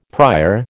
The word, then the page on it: prior: American English